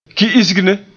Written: ki'isgne

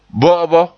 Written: bo'obo

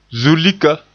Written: zulika

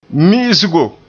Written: mi'isgo

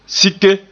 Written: sike

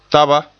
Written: taba

